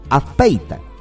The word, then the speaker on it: Azpeitia: Azpeitia es, ¿no? Así es. Así se pronuncia, ¿no es cierto, Chalita? Azpeitia.